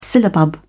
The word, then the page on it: syllabub: British English